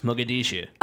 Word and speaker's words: Mogadishu: That's where Black Hawk Down was, Mogadishu.